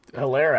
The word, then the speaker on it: Helaire: They showed they, they could even do it on the ground last last week with Helaire having I shouldn't I, I shouldn't say Helaire it's a silent H, but uh, great game on the ground.